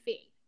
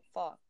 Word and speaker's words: pho: Oh, pho. Yeah. – Pho. Pho. I think people call it pho, but I think it's actually phi. – No, it's pho.